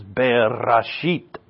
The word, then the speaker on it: Bereshit: Now, if you remember, you're not really supposed to remember this, but it's fun to say, is Bereshit. If you can roll your R's, it's even more fun to say.